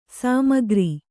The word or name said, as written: ಸಮಗಾರ